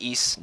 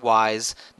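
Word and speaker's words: Ys: It's called officially "So You Want to Get Into East," and yes, it's pronounced East, not wise, not ease. It's like you say the word geese but drop the G, but you don't say it like Terry Bogard when he meets the man who killed his father and says geese. Not that way.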